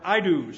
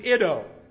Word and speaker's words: Iddo: Or Idu, somebody says, but I don't pronounce his name Idu, because I said that one time, and I've been in trouble ever since. And so I pronounce him Iddo.